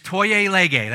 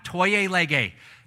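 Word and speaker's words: tolle lege: And so the first thing I want to show you, if you'd put that up on the screen, it's toye lege. That's how you pronounce it. It's Latin, toye lege.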